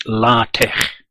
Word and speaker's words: LaTeX: This is why the people that use it pronounce it LaTeX.